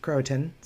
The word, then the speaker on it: Croatan: And so I'm digging it out, because I actually went through Roanoke Island and Croatan Sound is... That's how that's pronounced, Croatan Sound, when my family and I went to Nag's Head this past weekend.